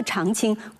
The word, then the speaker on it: 长卿: 西汉的大文豪司马相如，他的字叫长卿，长表示他是家里的老大，卿是对男子的美称。孙子兵法的作者孙武，他也字长卿，也是家里的老大。甚至我们还可以合理的推测，唐代的大诗人刘长卿，他的长卿恐怕也应该读作长卿。